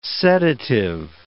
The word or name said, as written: sedative